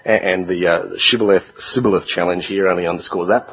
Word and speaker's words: shibboleth: If he replied no, they said, "All right then, say Shibboleth." If he said Sibboleth, because he couldn't pronounce the word correctly, they seized him and killed him at the fords of the Jordan.